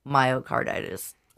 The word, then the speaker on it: myocarditis: And I would like to know if that is any part of myocarditis.